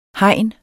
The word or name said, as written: hegn